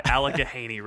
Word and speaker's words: Allegheny: I like how you add another H in. Like it's A-L-L-E-G-H-H-E-N-Y. Allegheny River. – That's how it's pronounced.